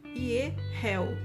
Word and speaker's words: Yehahel: Ele é escrito Y-E-H-A-H-E-L e a gente pronuncia o H como um R duplo. O I, o E, o primeiro E como um E com acento circunflexo e o último E como um E com acento agudo.